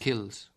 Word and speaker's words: kilns: The lime was produced in kilns, always pronounced kills with the n silent.